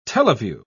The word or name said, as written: teleview